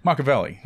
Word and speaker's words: Machiavelli: Machiavelli. – Machiavelli. No. – Machiavelli. – That's a Germanic. In Germanic languages, we like to put the stress in the first syllable.